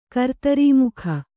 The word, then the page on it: ಕರ್ತರೀಮುಖ: kartarī mukha